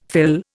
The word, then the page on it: fill: Indian